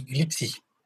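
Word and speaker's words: glibc: Et au passage, dans le genre prononciation, il, paraît que glipsé, ça dit glipsy.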